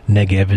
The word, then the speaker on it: Negev: Nakab is the Arab pronunciation. Negev is the Israeli Hebrew pronunciation.